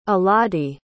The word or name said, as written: alodie